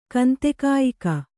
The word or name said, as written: ಕಂತೆಕಾಯಿಕ